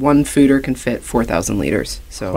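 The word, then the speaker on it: foeder: Is that how you say it? Food is it? – Fooder. – Fooder. Okay.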